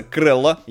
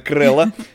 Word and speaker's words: Crello: Э, сервис называется Крелло. Я правильно произношу, Ксения? – Абсолютно. – Вот, не крыло, а именно Крелло.